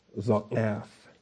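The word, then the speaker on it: za'aph: Z-A apostrophe A-P-H. Zotgaf. I've always been terrible at pronouncing my ayin. It's some people just chicken out and they use a G sound for it, but it's not technically a G sound. It's further back in the throat than that.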